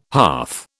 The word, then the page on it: hearth: British English